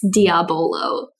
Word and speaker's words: Diabolo: Today we are enjoying Diabolo, a sparkling French lemonade, mint citron flavored. – He's not just mispronouncing Diablo, it's Diabolo.